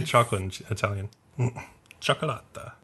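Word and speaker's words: Cioccolata: It's like chocolate, so it's like chocolata.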